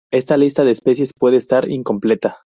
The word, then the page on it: incompleta: ispanskii